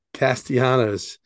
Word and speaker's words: Castellanos: I mean, Castellanos, first of all, you keep on saying his name wrong. Castellanos.